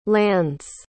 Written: lands

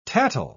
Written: tattle

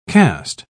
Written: caste

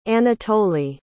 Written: Anatoly

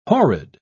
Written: horrid

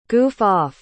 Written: goof-off

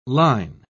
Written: line

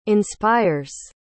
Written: inspires

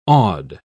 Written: awed

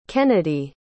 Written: kenadie

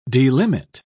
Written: delimit

